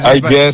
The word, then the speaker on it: Alguer: Alguer senza la si pronuncia senza.